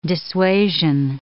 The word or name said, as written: dissuasion